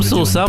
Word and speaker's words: соусам: Э, Антон, почему, вот э, мне кажется, это вот наша отечественная такая традиция, и всем, как говорится, соусам, я специально всем соусам, правильно ударение.